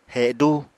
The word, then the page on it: hedú: apatani